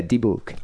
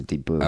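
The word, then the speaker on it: dybbuk: Is that how you pronounce that? – Yeah, dibuk. – I thought it was dibbuk. – Nah, it's pronounced a dibuk. – Okay, all right. – But a dibuk, well, at least that's how you'd say it in Hebrew.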